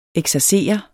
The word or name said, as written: eksercere